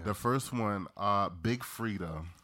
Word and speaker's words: Freedia: The I is silent. – It's Freedia. – Freedia.